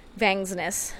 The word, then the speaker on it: Vangsness: Vangsness. – Yeah. Say it right. – It's a lot of S's.